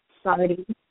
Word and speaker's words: Sardie: If you are on Facebook and you desire to find my Soul's Facebook page, you can friend me at Sardi Adisa Izzard, and because that is a funny name indeed, my first name is spelled S-A-R-D-I-E.